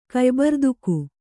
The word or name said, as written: ಕಯ್ಬರ್ದುಕು